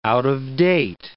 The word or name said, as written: out-of-date